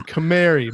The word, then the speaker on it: Camari: It's like a canary, like you send into the coal mines, but with an M. – Camari.